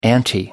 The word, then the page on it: ante up: American English